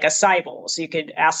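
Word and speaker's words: açaí: Acai, A-C-A-I, acai bowl.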